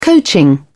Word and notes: coaching: British English

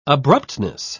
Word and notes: abruptness: British English

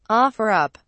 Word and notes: offer up: British English

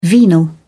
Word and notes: venal: British English

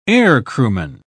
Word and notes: aircrewman: British English

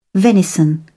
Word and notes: venison: British English

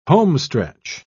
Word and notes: homestretch: British English

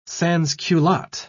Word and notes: sansculotte: British English

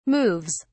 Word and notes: moves: British English

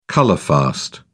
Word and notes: colour fast: British English

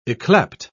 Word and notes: yclept: British English

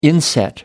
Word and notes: inset: American English

